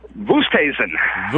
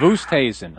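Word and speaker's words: Oosthuizen: Oosterhuizen. – Oosterhuizen.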